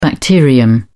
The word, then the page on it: bacterium: British English